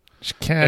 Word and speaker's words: Chicago: Chicago. – Emphasis on the second syllable.